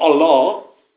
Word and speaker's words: Allah: Übrigens, also muss man auch mal sagen, es heißt nicht Allah, sondern Allah. Das ist die korrekte Aussprache, da merkt man schon das herrische, befehlstönerische dabei.